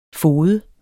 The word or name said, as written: fode